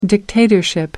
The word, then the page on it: dictatorship: American English